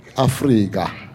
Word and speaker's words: Afrika: And we don't pronounce it Africa or Africa. We pronounce it Afrika.